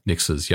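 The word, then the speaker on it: Nixxes: They also acquired Nixxis. I believe that's how you pronounce their name. – Nixxis, yep.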